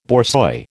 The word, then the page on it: borzoi: American English